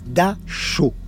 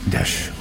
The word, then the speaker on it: Dachau: Il va s'évertuer à prononcer le nom du camp à la française. C'est-à-dire qu'en allemand, c'est Dachau. Mais lui dira toujours, et il précise même en mouillant la deuxième syllabe, Dachau.